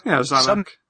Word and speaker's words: Simic: Zamek guild, maybe? Is that how you pronounce his name? – Yeah, Zamek.